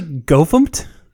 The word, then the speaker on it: gofumpt: Did you just say GoFumpt? – Yeah, that's how you say it.